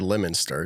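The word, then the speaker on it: Leominster: It's said Lemonster. It's spelled funky because it's New England.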